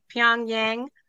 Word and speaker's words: Pyongyang: Pyongyang.